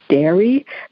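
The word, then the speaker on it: Darë: It's pronounced Dairy, but it's D-A-R and then an E with an umlaut over it.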